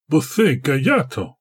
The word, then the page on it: bo-the-ga-ya-toh: Galindri